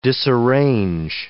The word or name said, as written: disarrange